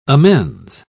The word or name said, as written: amends